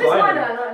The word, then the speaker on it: liner: Or liner notes, however it's pronounced. Spelt linear, though. – It's liner. – It's liner. Linear notes is something else.